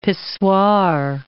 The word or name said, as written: pissoir